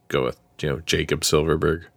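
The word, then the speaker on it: Silfverberg: Silverberg, the F is silent.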